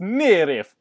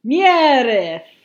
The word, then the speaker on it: NIIRYF: Zoza the letters, for not have I repeat you first, and it's pronounced Nirif.